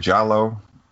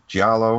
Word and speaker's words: giallo: Giallo, Giallo.